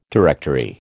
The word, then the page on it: directory: American English